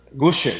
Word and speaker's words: Guschert: Como é que se pronuncia seu sobrenome, doutor? – Guschert, desse Guschert. – jeito mesmo.